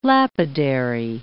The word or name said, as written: lapidary